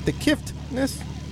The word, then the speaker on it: Kiffness: Did I say that right? The Kiffness? – The Kiffness. There's okay. – No T.